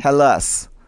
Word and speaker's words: Halas: I mean, you're allowed to say it however you like, but I guess Halas is the right pronunciation. – Halas.